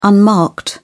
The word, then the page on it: unmarked: British English